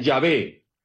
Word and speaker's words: Yahvé: Cuando a las cuatro letras sagradas que se pronuncia en Yahvé, se les puso las vocales de Adonai, salió Jehová. Por lo tanto, es un artificio. Y es verdad que en algunas Biblias, a partir del siglo 8 o 9, se fue colocando Jehová en vez de Yahvé, pero luego la iglesia remarcó que hay que volver a la pronunciación correcta.